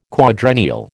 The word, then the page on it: quadrennial: American English